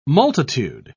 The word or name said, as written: multitude